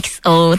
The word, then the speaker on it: EXO: 如果是英文字的话是 EXO，但是呢我知道一不发音，所以要念 XO。